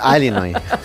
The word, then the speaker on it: Illinois: Es un estado de Estados Unidos que se pronuncia así. Es sí, Illinois, pero es Illinois.